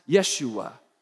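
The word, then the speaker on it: Yeshua (ישוע): The name Jesus in Hebrew is actually pronounced Yeshua, and it means Yahweh saves.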